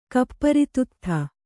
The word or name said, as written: ಕಪ್ಪರಿತುತ್ಥ